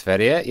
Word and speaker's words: Sverige: It's Sverige, yeah.